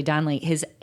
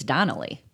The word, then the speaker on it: Donlea: Yeah, so I would like to say my name is pronounced just like Chelsea. – Donnelly. – Chelsea. No one pronounces Chelsea or Chelsea. Somehow Donlea comes out a lot. – I know, I'm sure. – It's just like Chelsea, so Charlie Donlea.